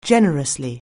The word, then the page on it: generously: anglais